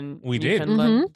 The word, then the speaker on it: Newfoundland: So we learned how to become initiated as a Newfoundlander. Mm-hmm. Yeah. Newfoundlanders. – I did not learn how to pronounce that. – This is Newfoundland.